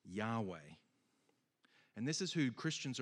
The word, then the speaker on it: Yahweh: Obviously, we write language differently now, but the best way that we know how to pronounce this name is Yahweh.